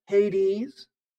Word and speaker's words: Hades: The Greek word in the book of Revelation is translated Hades, or as some people pronounce it, Hades.